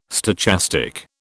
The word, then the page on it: stochastic: British English